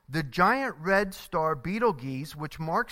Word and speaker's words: Betelgeuse: The star is Betelgeuse, or sometimes pronounced Betelgeuse.